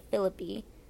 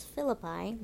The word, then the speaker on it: Philippi: magistrates in the Roman colony of Philippi. Just realized I've been saying that wrong. It's Philippi, my bad.